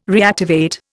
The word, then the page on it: reactivate: Indian